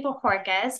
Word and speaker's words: Bohorquez: My name is Casey Bohorquez. Try saying that if you phonetically read it. It's always fun to listen to them try to pronounce that.